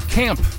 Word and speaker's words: Caamp: Camp. It is spelled C-A-A-M-P.